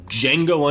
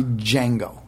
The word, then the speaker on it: Django: But you can call me Django. But don't call me the Django. – No, you wouldn't do that. D is silent.